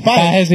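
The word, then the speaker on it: Pagés: Pero dicho Andy Pages. Estadounidenses dicen Pages. Y es válido. – Y se queda así. – Pero lleva una tilde.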